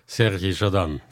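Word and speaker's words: Sergej Chadan: Utan, ska vi se om jag klarar uttalet, Sergi Chadan. Nej. – Ja. Sergi Chadan. Tvärtom. – Okej, det är bra. Nu har vi fått ett korrekt uttal.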